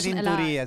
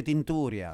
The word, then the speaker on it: Tinturia: No, non si dice Tinturia, si dice Tinturia.